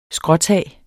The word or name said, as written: skråtag